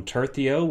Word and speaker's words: tercio: But now we've landed on tercio, which I'm told is correct.